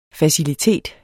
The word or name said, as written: facilitet